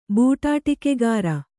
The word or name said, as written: ಬೂಟಾಟಿಕೆಗಾರ